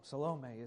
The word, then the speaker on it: Salome: Her daughter's name, we know that historically, is Salome. Salome is how you say her name.